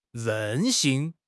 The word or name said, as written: 任性